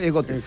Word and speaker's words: Gautier: Sí, se escribe Gautier, es Gautier.